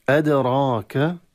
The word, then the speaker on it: أدراك: أدراك، قلقلة في الدال قلقلة صغرى، أدراك الراء تفخم مع الألف التي بعدها، وللعلم التي بعدها من حيث الرسم ياء، ولكن من حيث النطق ألف.